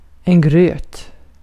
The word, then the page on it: gröt: /ɡrøːt/